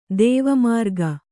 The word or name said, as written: ದೇವಮಾರ್ಗ